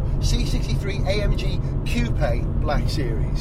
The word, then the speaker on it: C63 AMG Coupe Black Series: How do you say it, Rob? C63 AMG Coupe Black Series. – Spot on.